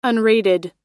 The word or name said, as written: unrated